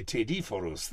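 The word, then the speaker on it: tedoforo: That's what they, tediforus. It's been in Italian, you know. Taida is a torch. And forus, the carrier of the torch. Tediforus.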